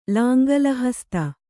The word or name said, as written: ಲಾಂಗಲಹಸ್ತ